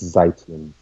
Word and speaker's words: Zeitlin: Ben Zitlin, Beasts of the Southern Wild. I know I butchered that name. – That's Zeitlin. – Zeitlin.